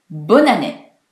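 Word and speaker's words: Bonne année: こちらは女性名詞ですので、前につくボンが、ブンヌに変わり、ブンヌアネ、ブンヌアネという言葉なのですが、こちら一緒に早く発音すると、ボナネ、ボナネ、ボナネという発音をします。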